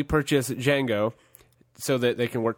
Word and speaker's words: Django: He's actually a bounty hunter, and he's looking for this particular slave, Django. The D is silent, by the way.